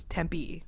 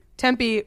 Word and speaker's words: Tempe: It's not Tempe. I think it's Tempe, just Tempe, not Tempe. Tempe is you're saying the same thing. – No, Tempe. – Tempe. – Tempe with the emphasis on the first syllable versus Tempe.